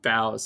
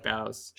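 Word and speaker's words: Boughs: I don't know how to pronounce that word. Is it bow? – Bows. – Bows? – Bows.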